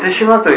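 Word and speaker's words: 豊島: 手島は皆さん、なんとなく産業廃棄物のね、イメージがあると思うんですよね。大量のね、廃棄物が捨てられて、それを処理するのに何年も何年もかかってね、もう本当に大変なことだった。ね。ところが手島というのはね、その豊かな島と書いて手島と読むので、小豆島の隣にある大きな島なんですね。小豆島に次いで大きいと思いますよね。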